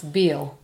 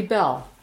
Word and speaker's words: Bell: A lot of people pronounce Beale. – Right. – I mean, you know, we like to look at the structure of a word and sound it out. But it's actually Bell.